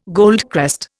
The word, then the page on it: goldcrest: Indian